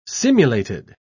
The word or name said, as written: simulated